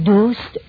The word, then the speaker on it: دوست: چون دوست و دشمن او هستند رهزن او، دوست باید خوانده شود دوست به تلفظ قدیمی کلمه.